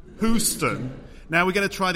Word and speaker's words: Houston: The name of the US city Houston in American English has a silent O and a pronounced E. So one could spell it phonetically as Houston. Hugh as in, I will use my axe and hew this log into a beam. Stun as in, I will use my ray gun to stun the alien invaders.